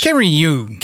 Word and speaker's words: Carreyrou: Carreyrou.